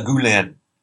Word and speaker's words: Gulen: Fethullah Gulen, it's pronounced, by the way, Gulen. Fethullah Gulen practices a Turkish Sufi light, if you will, L-I-T-E.